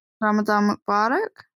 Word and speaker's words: Ramadan Mubarak: Ramadan Mubarak.